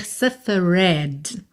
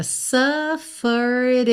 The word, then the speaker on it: suffered: Cuidado para não dizer suffered, tá? Esse suffered termina com ed, mas o e final não é pronunciado. Então você canta suffered enough, ok? Suffered enough. Enough é N-O-U-G-H.